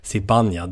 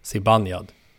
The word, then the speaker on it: Zibanejad: Sibanejad, det är det. Sibanejad.